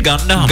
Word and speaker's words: Gundam: Gundam? – Gundam.